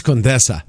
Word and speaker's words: Condesa: I think it's Condesa. – It's Condesa.